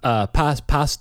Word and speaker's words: pas: Pas de deux. How do you say it? What's the... – I think the S is silent. I think it's pa.